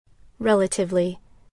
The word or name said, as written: relatively